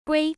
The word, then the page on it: GUI: American English